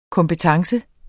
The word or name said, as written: kompetence